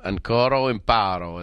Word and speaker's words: Ancora imparo: Ancora imparo is what I've been told to say is the proper pronunciation.